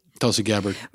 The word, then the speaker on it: Tulsi Gabbard: Tulsi Gabbard.